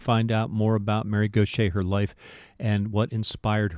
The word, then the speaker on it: Gauthier: Mary Gaucher, and please pronounce it correctly, G-A-U-T-H-I-E-R. Yeah, just like our programmer, Shay. He spells that different, but they both sound the same.